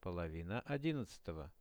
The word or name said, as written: polovina 11-ogo